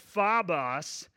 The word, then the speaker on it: phobos: The word fear here is a Greek word. It's actually pronounced phobos.